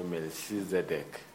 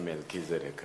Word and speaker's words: Melchizedek: Some pronounce it, they say Melchizedek. So I prefer Melchizedek.